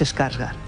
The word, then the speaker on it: Skarsgård: Hay que buscar el dato de qué número de pelis hablas al año que incluyen alguna Sarsgaard. Dobles dígitos hay. – Los Sarsgaard no es Skarsgård.